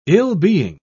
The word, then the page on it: ill-being: British English